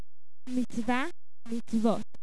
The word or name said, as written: mitzvah